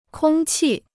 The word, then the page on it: 空气: kōng qì